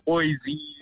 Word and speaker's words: Boyce: Is it Boyce or is it Boyce? – B-O-Y-C-E. – How do you say it? – It's Boyce. – It's Boyce, but black people say Boyce, ne? – Yeah, Boise. If they read my name the first time, it's Beyonce.